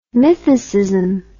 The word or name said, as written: mythicism